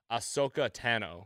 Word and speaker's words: Ahsoka Tano: Big Star Wars clone hero, Ahsoka Tano, if I'm saying that correctly.